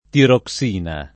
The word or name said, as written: tiroxina